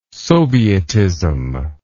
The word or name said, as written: sovietism